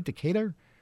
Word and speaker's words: Decatur: Is that how you say it, Bernie? Decatur? – Yes.